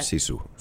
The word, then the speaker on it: sisu: Sisu.